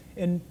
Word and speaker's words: en: The second one is the Greek word en, en, and we pronounce it in.